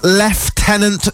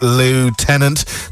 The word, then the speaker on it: lieutenant: It's pronounced Lieutenant. Since we are English, the Americans pronounce it Lieutenant.